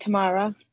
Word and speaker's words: Tamara: Yeah, that's correct, Tamara.